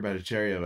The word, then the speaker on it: Bhattacharya: Like, you know, uh, Dr. Bhattacharya, I think is, is how you pronounce his name,